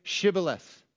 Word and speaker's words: Shibboleth: If he answered, "No," they told him, "Please say Shibboleth."